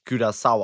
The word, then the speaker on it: Curaçao: Ja, ich habe tatsächlich gegoogelt, es wird tatsächlich Curaçao ausgesprochen.